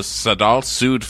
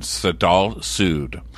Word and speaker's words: Sadal Suud: Okay, they are called the Sadal Sud Foundation. That is spelled S-A-D-A-L. S-U-U-D Foundation, Sadal Sud.